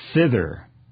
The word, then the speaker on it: thither: But he did compliment me because last week in verses 10 and 13, I pronounced thither right. – Wow. – Correctly. You know, wow. – I do struggle a little bit with the King James English from time to time.